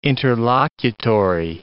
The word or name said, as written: interlocutory